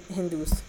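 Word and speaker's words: hindú: So not even Hindu, because H is not pronounced.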